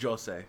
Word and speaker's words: José: And in the audiobook they do pronounce it Jose.